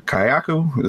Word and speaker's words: Kayako: But overall, I did like the look of the Kayako.